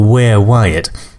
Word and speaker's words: WereWyatt: I think the band is called WereWyatt. It's all one word, as if it's kind of like a werewolf, but not really, because where is spelt W-E-R-E, so WereWyatt.